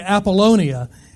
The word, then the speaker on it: Apollonia: Now, Brother Jim pronounced it a little bit different, and it almost messed me up because I worked so hard to say Apollonia. And he almost got me, but I'm back. Apollonia. So Paul went through Amphipolis, and he went through Apollonia to get to Thessalonica.